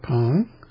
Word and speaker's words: Peng: It's P-E-N-G with an upward pitch change on the E, pronounced Pong.